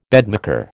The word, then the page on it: bedmaker: American English